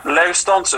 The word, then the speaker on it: Lausdans: Lausdans under That's what it's. Uh, that's the Norwegian pronunciation.